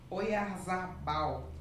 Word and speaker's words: Oyarzabal: O destaque foi o jogador, não sei falar esse nome, vou falar aqui do jeito que eu estou pronunciando, hein? Oyarzabal. É isso mesmo? É.